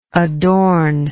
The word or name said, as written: adorningly